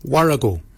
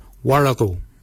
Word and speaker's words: worragol: Bakin is spelled B-A-G-I-N-Y. Bakin. Bakin is your beard. The upper mouth, we say Warrigal. Warrigal. Warrigal is spelled W-O-R-R-A-G-O-L. Warrigal. Warrigal is your upper mouth. For your nose, we say Moil. Moil is your nose. Moil is spelled M-O-O-L-Y.